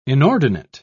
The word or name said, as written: inordinate